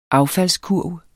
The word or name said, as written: affaldskurv